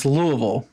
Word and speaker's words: Louisville: Yeah, it's Louisville. Louisville, Kentucky. And the telltale sign that you're talking to someone who's not from Louisville is that they'll say either Louisville or even worse, Louisville, which if somebody says Louisville, then they've either just moved or never paid attention. But the local pronunciation is Louisville.